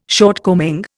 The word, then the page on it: shortcoming: Indian